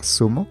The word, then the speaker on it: zumo: Zumo, como dicen en España, o si queremos usar el acento español, zumo. El zumo de naranja. – El zumo de naranja. – Exactamente. Pronuncian con el sonido. En la descripción de la pronunciación, este sonido se llama theta. Theta, que es la lengua entre los dientes para decir cereza o zumo.